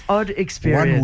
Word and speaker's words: Oddxperienc: It's O-D-D-X. – Exactly. – And without an E in the middle and without an E at the end. – Yes. – Oddxperienc.